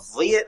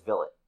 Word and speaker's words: Vliet: Vliet, I think he pronounces it. I've been saying it wrong in my head forever. Because I thought it was Villet. – Yeah. – But it's Vliet.